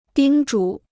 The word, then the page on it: 叮嘱: dīng zhǔ